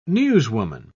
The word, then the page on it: newswoman: British English